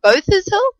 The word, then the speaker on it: Botha's Hill: Bottom of Botha's Hill? Yes, that's how you say it.